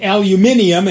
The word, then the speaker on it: Aluminium: It'll run a new OS called Aluminium, and yes, they both spelled and pronounce it with the extra I.